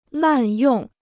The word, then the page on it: 滥用: làn yòng